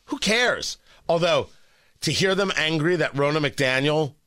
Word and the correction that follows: Ronna McDaniel: was hired, well, Ronna McDaniel, I should say her name properly, that Ronna McDaniel was hired.